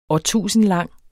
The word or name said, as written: årtusindlang